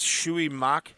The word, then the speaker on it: Shui Mak: So then we got, Shui Mok, I think is how you say it. S-H-U-I is her first name, and then M-A-K is her last name, so I think it's Shui Mak, age 30.